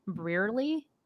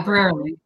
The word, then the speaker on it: Brearley: Brearley. – Uh, yeah, Brearley.